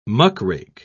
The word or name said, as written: muckrake